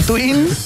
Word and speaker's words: Tuin: T-U-I-N, tal cual suena.